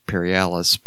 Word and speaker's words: Perialis: It was recorded at Pyramid Sound Studios, Ithaca, New York, which is a tangential shout-out to the Alex Paralysis. That's not how he pronounces his name. It's Alex Perialis, but... I think on a prior episode, we called him Alex Paralysis.